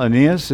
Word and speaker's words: Aeneas: And the reason I know how to pronounce that name so well, Aeneas, is because there used to be a football player on the Cardinals.